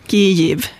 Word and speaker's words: Kyiv: Vi har försökt göra uttalsinstruktioner som är lätta att förstå och lätta att tillämpa på svenska, och det låter inte exakt som på ukrainska, utan Kyiv är okej. – Kyiv, bra.